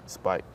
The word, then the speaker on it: Spight: Spight. – Spight now. He said, just like light, say Spight.